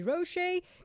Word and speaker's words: Rochie: मेरा नाम है मिर्ची रोशे, नाम की स्पेलिंग है R-O-C-H-I-E.